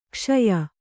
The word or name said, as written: ಕಷಾಯ